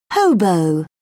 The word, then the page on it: hobo: British English